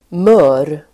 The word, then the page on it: mör: [mö:r]